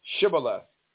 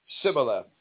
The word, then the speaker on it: Shibboleth: And they would say to him, 'Then say, Shibboleth.' And he would say, 'Sibboleth,' for he could not pronounce it right.